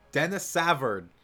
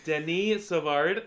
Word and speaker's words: Denis Savard: Dennis Savard. What a beautiful man. Montreal Canadiens. – Well, yes, centerman. What it's correctly pronounced, and Denis Savard.